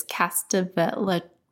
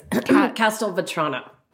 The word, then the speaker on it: Castelvetrano: It's Castelvetrano. – No, you said, yes. Castelvetrano.